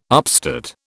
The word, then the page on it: upstate: British English